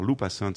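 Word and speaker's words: Lew Passant: Voilà, et là j'ai été conquis par leur cuvée prestige chardonnay 2006 qui s'appelle Lou Passant. Alors Lou Passant, ça s'écrit L-E-W Passant,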